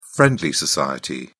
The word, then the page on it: friendly society: British English